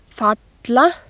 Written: falla

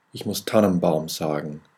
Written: tannenbaum

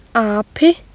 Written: api

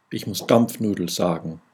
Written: dampfnudel

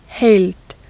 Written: huld

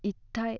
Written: ittai

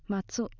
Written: matsu

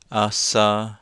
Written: asa

